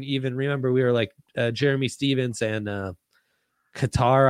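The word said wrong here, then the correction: Kattar: I forget the exact pronunciation. He took Calvin Cater.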